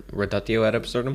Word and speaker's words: reductio ad absurdum: they definitely do the adducto ad absurdum. Is that how you say it? – Reductio ad absurdum? – Yeah, that's what it is.